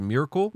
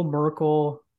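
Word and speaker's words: Myrkul: Is it Mirkel? – Mirkel, you know, either pick your poison. – Okay. – You're good either way.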